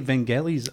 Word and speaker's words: Vangelis: I used to say Vangelis, but then, of course, when I got older, I realized it was actually a hard G.